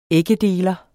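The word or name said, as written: æggedeler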